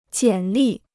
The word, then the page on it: 简历: jiǎn lì